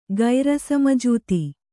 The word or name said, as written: ಗೈರಸಮಜೂತಿ